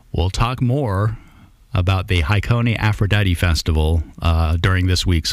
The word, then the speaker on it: Hakone: 55 years ago this week, August 6th and 7th, 1971, Floyd performing at Hakone. Am I pronouncing that right? – Hakone. – I guess I am.